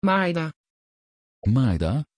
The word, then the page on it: Maida: Dutch